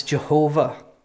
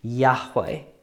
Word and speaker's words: Yahweh: Now, that's not how this name would have been pronounced in Hebrew. The only problem is that because no one said the word, we don't really even know how it should be pronounced. Those who know more about the Hebrew language than I do seem to think that it's more likely to be pronounced Yahweh.